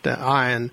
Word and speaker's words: Ayn: And this Ayn Rand, Anne, I guess is not the way it's pronounced, Ayn Rand, is actually her real name is Alyssa Rosenbaum.